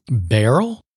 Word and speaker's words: Beryl: Beryl? – Beryl. It's spelled like Merrill, but with a B.